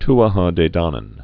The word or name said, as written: Tuatha De Danaan